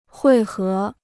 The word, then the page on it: 汇合: huì hé